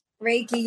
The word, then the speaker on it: Reiki: A certified sound healer and let's see if I can say this right. Reiki practitioner. – Reiki, yep. – Reiki. All right. Reiki.